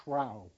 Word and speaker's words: shrew: There is the fact that the word shrew, it would have been pronounced shrow in Shakespeare's day, which is why some of you reading it may have noticed some odd puns here.